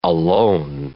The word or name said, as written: alone